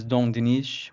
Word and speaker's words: Dinis: Yeah, Denis, modernly pronounced as Dom Dinis, with that Dom being the honorific, was the king of Portugal from 1279 to 1325.